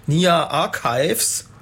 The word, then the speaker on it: Nia Archives: Ja, das war Vertical von Nia Archives. Habe ich das richtig ausgesprochen? – Hast du fein ausgesprochen. Richtig gut.